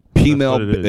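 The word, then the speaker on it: peameal: P-mail, it's P-mail bacon. – Do you know how to spell that? – Yeah, it's like female with a P.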